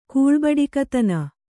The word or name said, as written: ಕೂಳ್ಬಡಿಕತನ